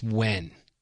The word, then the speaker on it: Nguyen: His name is pronounced Wen.